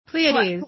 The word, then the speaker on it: Pleiades: The constellation, I'm going to say this wrong, Pleiades? – Pleiades.